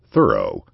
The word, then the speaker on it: Thoreau: All sources agree that they put the emphasis on the first syllable of their name. According to some, they pronounced it like the word thorough. According to others, they sounded the O as it sounds in the words more and store, thorough.